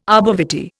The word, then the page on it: arbor vitae: Indian